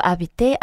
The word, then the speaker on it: habiter: Je veux habiter à Paris. – Je veux habiter à Paris. – 그렇죠. Je veux habiter 하고 연음하셔도 상관없습니다. – 네. – 아슈가 발음이 나지 않기 때문에 익스하고 아하고 연음시키셔도 상관없어요.